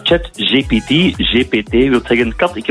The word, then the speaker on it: ChatGPT: Ja, technologie-expert, nu heb ik het juist uitgesproken, hè? ChatGPT. – Uiteraard. – Ik zeg altijd ChatGTP.